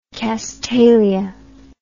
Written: castalian

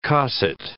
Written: cosset

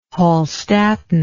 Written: Hallstattan

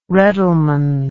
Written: reddleman

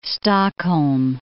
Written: Stockholm

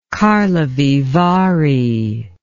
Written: karlovy vary

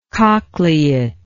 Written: cochleate

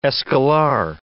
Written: escolar

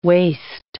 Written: waste

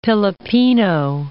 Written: Pilipino

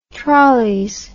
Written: trolleys

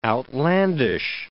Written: outlandish

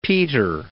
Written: Peter I

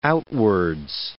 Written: outwards

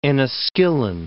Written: Enniskillen

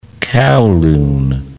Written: Kowloon

